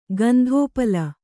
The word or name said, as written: ಗಂಧೋಪಲ